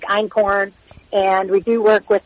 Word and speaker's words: einkorn: So ancient grains would be really, honestly, einkorn. And that's spelled E-I-N-K-O-R-N. – Right. – And it's not a corn. People are always like, it's corn? No. It's actually a German word that, you know, essentially means, you know, one grain and one the great-grandparent of all varieties of wheat.